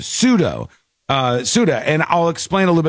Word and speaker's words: Ceuta: It's pronounced Ceuta, in case you see it somewhere, but it's a C-E-U-T-A.